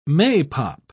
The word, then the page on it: maypop: British English